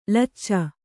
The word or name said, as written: ಲೊಚ